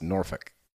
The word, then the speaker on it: Norfolk: To his credit, Norfolk, Virginia is pronounced Norfolk.